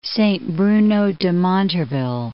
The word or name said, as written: Saint-Bruno-de-Montarville